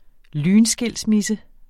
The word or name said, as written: lynskilsmisse